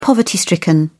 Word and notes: poverty-stricken: British English

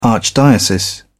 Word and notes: archdiocese: British English

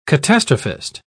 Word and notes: catastrophist: British English

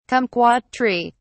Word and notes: kumquat tree: British English

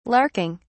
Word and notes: larking: British English

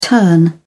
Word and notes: turn around: British English

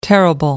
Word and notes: terrible: American English

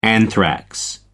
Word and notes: anthrax: American English

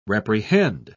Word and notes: reprehend: British English

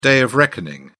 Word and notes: day of reckoning: British English

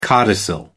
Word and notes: codicil: American English

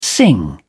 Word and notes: sing along: British English

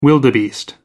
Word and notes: wildebeest: American English

